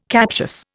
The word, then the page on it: captious: Indian